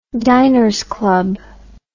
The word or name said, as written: Diners Club